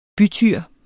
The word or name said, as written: bytyr